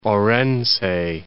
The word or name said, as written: Orense